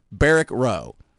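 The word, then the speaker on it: Barracks Row: Then we get in, wake up, go eat, go have a few drinks at a few bars in this neighborhood called, and it's hard to remember how to say it right because it's almost spelled by, like, Barack, but it's Barracks, Barrack Row.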